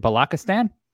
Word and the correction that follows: Balochistan: Balochistan, yeah.